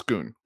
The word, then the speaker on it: Schoonmaker: A lot of people say Schoon. It's Schoon.